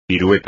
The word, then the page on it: pirueta: espagnol